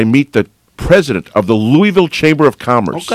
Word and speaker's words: Louisville: The actual pronunciation is that right? – Louisville. It's named after Louis.